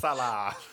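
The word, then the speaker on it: Elsaesser: Elsasser.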